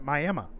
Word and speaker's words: Miami: One of my customers is in Miami, Oklahoma, and they pronounce it Miami.